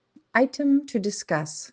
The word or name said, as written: item to discuss